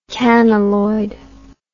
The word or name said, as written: canneloid